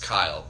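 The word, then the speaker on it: Keil: This episode of Grown Man Coloring is brought to you by Josh Kyle, JoshKeil, but pronounced Kyle because he's from the Deutschland, I think, or his like name.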